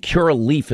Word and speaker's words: Curaleaf: Now, this is Curaleaf is how you pronounce it.